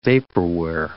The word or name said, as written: vaporware